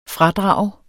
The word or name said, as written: FRADRAGES